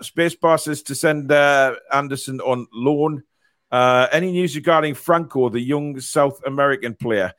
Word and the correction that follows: França: Well, last we heard, John, that the, you know, the, França, yeah, please say it right. It's França.